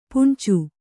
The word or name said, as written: ಪುಣ್ಚು